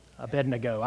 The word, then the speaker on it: Abednego: Shadrach, Meshach, and Abednego. Hallelujah. Abednego. I know how to pronounce it.